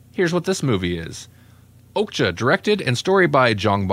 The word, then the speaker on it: Okja: Okja. – Okja, and I think that's how they were saying it in the movie.